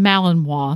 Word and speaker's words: Malinois: Now, this morning, before we got started, as usual, you were helping your Belgian Malinois.